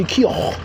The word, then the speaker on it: Keough: It's pronounced Riley Keough. Like Van Gogh.